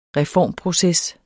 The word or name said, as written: reformproces